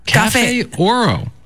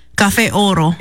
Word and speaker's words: Cafe Oro: Cafe Oro. – Oh my gosh, don't say it like that. Cafe Oro.